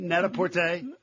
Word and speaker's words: Net-a-Porter: Is that how you pronounce that? – Well, it's French, after all. – Very good. – Prêt-à-porter, net-à-porter. You get it?